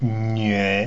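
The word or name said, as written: nüe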